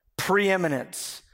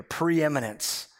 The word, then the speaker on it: preeminence: Preeminence. That's like a good like $10 word right there. Preeminence. Preeminence. Four full syllables.